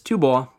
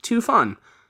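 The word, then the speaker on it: Tu Bo (吐蕃): Tibet is Tu Bo, not Tu Fan, but I'm just gonna go ahead and call them Tibet, because I can.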